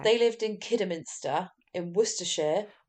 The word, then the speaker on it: Worcestershire: But it's Worcestershire.